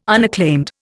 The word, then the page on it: unacclaimed: Indian